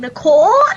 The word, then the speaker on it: Nicole: Nicole is spelled N-I-C-O-L-E, and Tanya is T-O-N-Y-A.